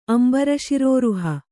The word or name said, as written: ಅಂಬರಶಿರೋರುಹ